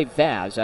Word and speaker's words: Vaz: It's against Javier Vaz. The pitch, that's in there, strike one. I should say Vaz, I apologize. Javier Vaz. – Rhymes with jazz.